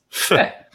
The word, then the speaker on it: feh: And then my favorite, which I had to look up, is spelled F-E-H, which is fe. No, it's fe.